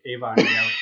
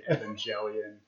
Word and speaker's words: Evangelion: So no hard G. They were saying Evangelion.